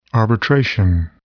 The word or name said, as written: arbitration